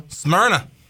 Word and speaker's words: Smyrna: Smyrna.